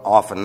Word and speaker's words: often: You know what often means? And by the way, it's not often. The T is silent in the English language in that particular word.